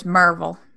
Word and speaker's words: Maryville: If you're from around here, it's Merville.